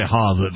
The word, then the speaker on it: Harvard: You have to say Harvard. – Harvard. – You can't pronounce the R in Harvard. It's Harvard.